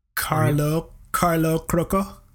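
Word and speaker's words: Carlo Crocco: Carlo Croco.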